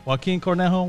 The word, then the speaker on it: Joaquin Cornejo: Joaquin Cornejo, welcome to the podcast, brother.